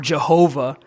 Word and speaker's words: Jehovah: The reason we pronounce it Yahweh or Jehovah is the vowels were taken from Adonai and placed underneath the consonants of Yahweh to give us a pronunciation of the name.